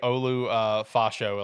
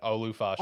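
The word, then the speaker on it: Olu Fashanu: Say Olu Fasho, like Tyler said in the chat. Olu Fasho.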